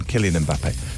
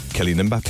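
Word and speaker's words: Kylian Mbappé: Kylian Mbappe. Kylian Mbappe. – Captain, can you say the name? – Kylian Mbappe.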